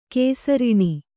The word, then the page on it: ಘುಸೃಣ: ghusřṇa